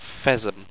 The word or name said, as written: ffesm